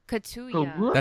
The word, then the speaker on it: Kaluuya: Let me see if I can pronounce it. Kaluuya? – Kaluuya? – Kaluuya. – That's an L.